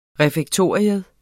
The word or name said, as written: refektorium